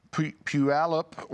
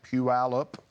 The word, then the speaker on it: Puyallup: Berna, Puyallup, Washington. Somebody got on to me for not saying that right. Puyallup, Washington, $100.